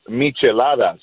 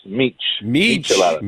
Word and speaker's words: Micheladas: The drink for the Traeger Hood today is pronounced Micheladas. Micheladas.